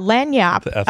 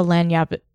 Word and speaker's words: Lagniappe: Lagniappe.